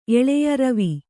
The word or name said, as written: ರವಿ